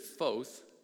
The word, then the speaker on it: Foth: Like you would think that Foth, F O T H, is pretty straightforward, but you've got one in two chance.